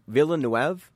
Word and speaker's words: Villeneuve: Villeneuve, that's what it was. Okay, I knew I was pronouncing his name incorrectly, and he corrected us. He's like, hey, it's actually pronounced this way.